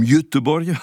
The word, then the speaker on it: Göteborg: je crois qu'on prononce quelque chose comme Göteborg, voilà, la grande ville portuaire du sud suédois, à côté mer du Nord, face à la pointe septentrionale du Danemark.